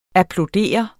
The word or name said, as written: applaudere